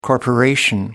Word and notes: corporation: American English

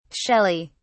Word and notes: shelley: British English

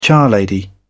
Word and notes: charlady: British English

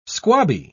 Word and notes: squabby: British English

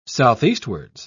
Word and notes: southeastwards: British English

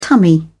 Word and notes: tummy: British English

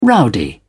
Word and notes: rowdy: British English

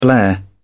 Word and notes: blare: British English

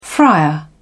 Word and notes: friar: British English

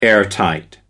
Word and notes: airtight: American English

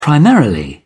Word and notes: primarily: British English

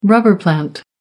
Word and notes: rubber plant: American English

